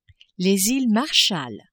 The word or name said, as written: Iles Marshall